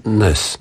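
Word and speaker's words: Næss: Co ten Ness, no, tak się wymawia to nazwisko? Tak, norweski filozof właśnie wymyślił, bo mówimy, że to jest cały wielki nurt.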